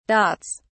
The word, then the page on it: dots: British English